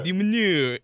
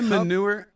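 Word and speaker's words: de Minaur: Demonor. – In the group stage of the ATP. – Is it manure? – Not manure.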